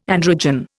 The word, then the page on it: androgen: Indian